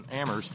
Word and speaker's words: Amherst: I had a note from Terry up in Amherst, where only the H is silent, who said, wait a minute, I subscribed on the 29th, and it's that thing with the subscription things with PayPal.